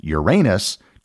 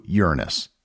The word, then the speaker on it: Uranus: Side note, it was around this time of the flyby that the accepted pronunciation of the planet changed from Uranus to Uranus.